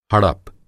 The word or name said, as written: हड़प